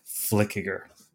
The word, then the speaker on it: Flueckiger: Flueckiger.